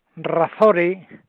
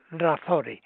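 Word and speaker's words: Razzore: Aquí estoy y he pensado para hoy contar la historia del circo Razore es como se pronuncia con su R y tal, solo que lleva dos Z, Razore, ¿eh?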